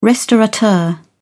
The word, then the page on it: restaurateur: British English